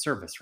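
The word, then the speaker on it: Servais: Everything that I've read, DePoto or Cervais has said, service, it's service, right? That's how you say it.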